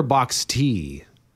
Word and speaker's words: boxty: Is it boxy or box tea? – I think it's boxies. Okay. Boxy. Oh no! Look, I've been to Ireland. Boxy. – It's spelled B-O-X-T-Y.